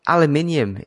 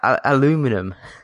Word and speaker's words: aluminium: It's like aluminium. It's not aluminum. – Actually, it's both.